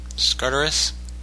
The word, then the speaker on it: Skartaris: Is that how you pronounce that? Skartaris? – Anyway. Close enough.